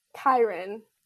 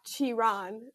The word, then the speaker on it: Chiron: Kyron. – Slay. – Because my older brother was like, it's not Chiron. He corrected me real quick.